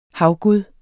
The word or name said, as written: havgud